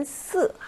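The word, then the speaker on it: 스 (四): 중국어에서 사의 발음은 스 하고 사성이에요.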